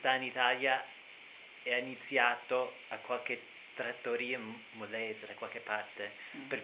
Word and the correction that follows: Molise: Molese, si dice Molise. – Dal Molise. – Molise.